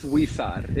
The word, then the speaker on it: Huizar: It's Huizar.